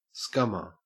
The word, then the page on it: scummer: Australian English